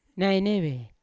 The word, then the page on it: naineve: Yawa